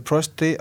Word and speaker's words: prostate: Is it, how do you say, is it prostate or prostate? I think I said it wrong before. – Prostate. – Yeah, I put an extra R in, right, just to clear that up.